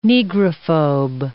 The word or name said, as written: negrophobe